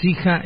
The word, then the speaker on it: Schija: ¿Y cómo se escribe Sija? – El original sería S-C-H-I-J-A.